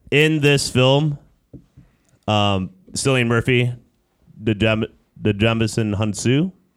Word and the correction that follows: Djimon Hounsou: Am I saying that right? Djimon Hounsou.